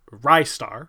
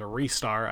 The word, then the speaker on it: Ristar: One of the games that I was definitely remembering as kind of a hidden gem was and there's kind of two ways I guess you could pronounce it. I've heard it both ways, but Ristar, R-I-S-T-A-R, which was released in 95. It's also said as a restar. I've heard that a couple of times.